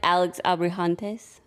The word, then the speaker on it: Alex Abrahantes: I liked Alex Abrahantes. Did I say that right? Woohoo! – Perfect. You got the perfect on that.